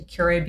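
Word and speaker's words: Kure: So I went to Carolina Beach for a couple of days, and we drove into Curay Beach, if I'm saying that right. – You are.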